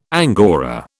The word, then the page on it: angora: British English